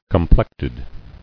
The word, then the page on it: Complected: com·plect·ed